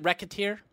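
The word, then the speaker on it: Recettear: Is it Wrecketeer? – Yeah, because they make a joke that if you just slightly mispronounced it, it would sound like Racketeer.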